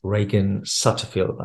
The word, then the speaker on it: Ragan Sutterfield: I am particularly excited, to have this guest, Ragan Sutterfield.